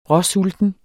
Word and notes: råsulten: [ ˈʁʌˈ- ]; [ ˈʁʌˌ- ]

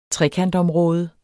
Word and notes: trekantområde: [ ˈtʁεkand- ]

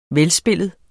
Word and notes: velspillet: -ˌspelˀəð; -ˌspeləð

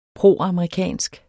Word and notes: proamerikansk: [ ˈpʁoˀɑmɑiˌkæˀnsg ]; [ ˈpʁoˀɑmʁiˌkæˀnsg ]

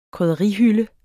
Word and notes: krydderihylde: [ kʁyðʌˈʁi- ]; [ kʁœðʌˈʁi- ]

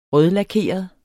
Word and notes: rødlakeret: [ ˈʁœðlaˌkeˀʌð ]; [ ˈʁœðlɑˌkeˀʌð ]

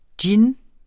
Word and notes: djinn: [ ˈdjin ]; [ ˈdjen ]

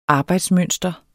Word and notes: arbejdsmønster: ˈɑːbɑjds-

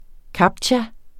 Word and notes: captcha: ˈkɑbtja

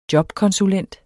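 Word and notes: JOBKONSULENTEN: Danish

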